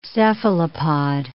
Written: cephalopod

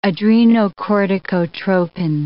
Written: adrenocorticotropin

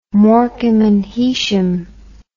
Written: morecambe and heysham

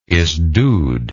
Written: Esdud

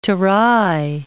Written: terai

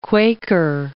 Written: Quaker

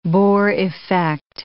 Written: Bohr effect

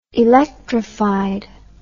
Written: electrified

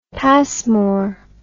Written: pasmore

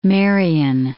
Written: Marion

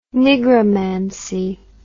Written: nigromancy

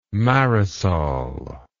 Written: Marisol

